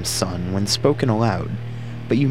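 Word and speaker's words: Sunn: This leads people to often mispronounce the band's name as Sun-O, but actually takes influence from the logo of the Sun brand of guitar amplifiers.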